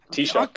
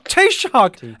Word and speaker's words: Taoiseach: Taoiseach. – Taoiseach.